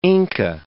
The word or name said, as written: Inca